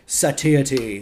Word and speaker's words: satiety: Satiate is the verb. Satiety.